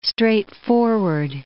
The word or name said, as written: straightforward